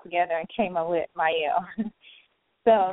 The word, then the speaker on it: Mielle: Okay, so first, I know a lot of people don't know how to pronounce it, so it's Mielle Organics, and it's a combination of my kids' names.